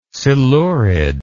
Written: silurid

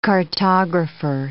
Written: cartographer

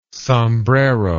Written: sombrero

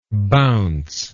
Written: bounceably

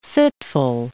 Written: fitful

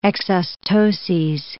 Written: exostoses

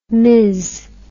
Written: miz

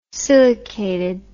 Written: silicated

